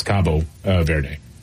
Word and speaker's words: Cabo Verde: Yeah, Cape Verde, or in Portuguese, it's Cabo Verde.